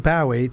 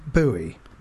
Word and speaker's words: Bowie: Anyone who pronounces it Bowie to rhyme with Howie or Towie is wrong.